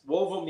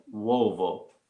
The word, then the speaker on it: uovo: Wovo means egg in Italian. U-O, V-O, Wovo.